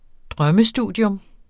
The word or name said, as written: drømmestudium